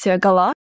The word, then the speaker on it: Гала: Вообще, я, конечно, читала, что на французский манер нужно говорить Гала, ударение на последний слог.